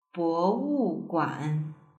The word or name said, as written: bowuguan